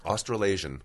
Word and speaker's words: Australasian: Australasian.